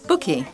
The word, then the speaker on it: Buky: B-U-K-Y. Bookie.